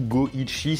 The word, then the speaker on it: Goichi: Et son pseudonyme Suda51 ça vient du fait que le 5 peut se prononcer Go en japonais et le 1 peut se prononcer Ichi, donc du coup Go Ichi 5 1 51.